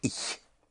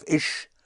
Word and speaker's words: ich: And I want to make sure right from the beginning of how to pronounce a sound which we don't have in English. And that is the sound of the ch, ich. I is spelled I-C-H, pronounced ich. It's a very soft S-H sound. So instead of ish, make it ich, ich. That sound is more in the back of your mouth.